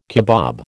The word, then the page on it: kebab: American English